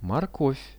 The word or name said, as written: morkov